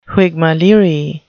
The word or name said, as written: whigmaleerie